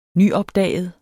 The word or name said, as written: nyopdaget